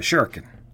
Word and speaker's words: shuriken: So it's pronounced shuriken, and the ri part in the middle is kind of like a rolling R, like you might find in French or Spanish.